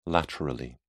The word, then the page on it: laterally: British English